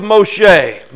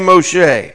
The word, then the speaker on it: Moshe: I want to look at the life of Moshe. Moses, you would call him. In Hebrew, you say his name Moshe.